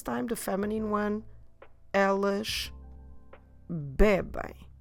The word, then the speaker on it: bebem: So stress the be, which is the second to last syllable, bebem. And then in the end, we do the sound ain. Make sure you keep your mouth open. Don't close your lips in the end. Bebem.